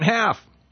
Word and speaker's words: halve: How do you pronounce that right? Half. It's H-A-L-V-E.